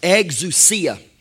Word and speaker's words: exousia: This word authority in the Greek is called, it's pronounced exousia. Exousia. E-X-O-U-S-I-A.